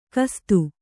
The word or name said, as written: ಖಸ್ಥ